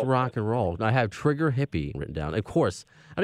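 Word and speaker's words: Trigger Hippy: also, a Trigger Happy. – Trigger Hippie. – Trigger Hippie. – H-I-P-P-Y.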